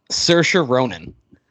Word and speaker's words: Saoirse Ronan: You know who can answer this question is my actress who I picked to play her, and I picked Saoirse Ronan, and I'm pretty sure I'm pronouncing that correctly. – Yeah, it's, I think you got it this time.